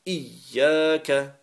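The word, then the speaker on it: إياك: Yaka, Iyyaka. So you should be able to hear the two ya's.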